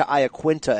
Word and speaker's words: Iaquinta: I feel like in the last year, people are committing to Iaquinta as the official pronunciation. You down with that? – Yeah, that's how the good old Long Islanders chanted in the crowd.